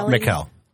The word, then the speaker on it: Michel: Annalise Mikkel, or Michael, I think it's Mikkel. – Spell it. – M-I-C-H-E-L. – Mikkel. – Mikkel. Okay, Michael has an A in there.